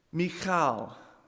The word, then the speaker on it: Michal: properly pronounce her name. Michal, it's that guttural that we aren't all that used to.